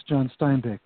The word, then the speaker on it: Steinbeck: She says, oh, that's John Steinbeck.